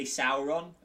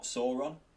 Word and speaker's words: Sauron: So it is definitely Sauron and not Sauron, for instance.